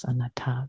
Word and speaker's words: anatta: And the third characteristic, anatta, anatta, anatta, anatta, yes, anatta, the stress on the last syllable, anatta.